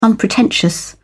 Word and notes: unpretentious: British English

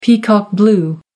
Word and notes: peacock-blue: American English

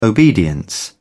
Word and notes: obedience: British English

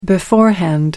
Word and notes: beforehand: American English

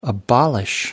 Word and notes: abolish: American English

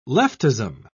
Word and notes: leftism: British English

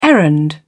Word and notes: errand: British English

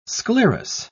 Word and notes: sclerous: British English

